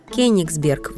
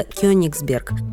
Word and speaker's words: Кёнигсберг: В советское время неправильно произносили старое название Калининграда – Кёнигсберг вместо Кёнигсберг, в том числе в фильме «17 мгновений весны».